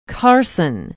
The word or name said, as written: carson